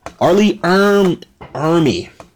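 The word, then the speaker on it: R. Lee Ermey: So when you see Ermy, I keep saying his name wrong. Forgive me, y'all. Y'all know how I am with these damn last names. Arlie Ermy. There we go.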